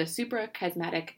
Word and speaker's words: Suprachiasmatic: You just published a paper recently in PNAS, and the title of this paper is Functional Network Inference of the Suprachiasmatic Nucleus. Did I pronounce that right? – That is correct.